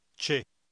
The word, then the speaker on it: c'è: Notice the combination C H E in number 8. It is pronounced che as distinct from C E which is pronounced c'è.